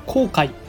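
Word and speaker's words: 黄海: こんにちは原っぱです 1904 年 8 月日本海軍 VS ロシア旅順艦隊黄色い海と書いて航海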